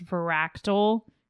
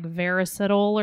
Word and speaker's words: Varactyl: Okay, so side note, when I was first reading this, I did not know it was pronounced Varactyl until we had talked about the book like a year later. I was like pronouncing it like Veracidal or something.